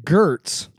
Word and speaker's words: Gaetz: Gaetz is Gertz. It's really hard for me to understand that people mess up both of those letters to get Gertz without realizing it.